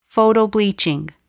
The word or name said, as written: photobleaching